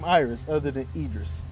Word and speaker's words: Idris: I think we pronounce it Idris. – Idris? We? – We as in black folks. We have... – I'm not Iris. – It's Idris. Okay. How you pronounce it? – Iris. – It has a D in it.